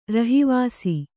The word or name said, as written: ರಹಿವಾಸಿ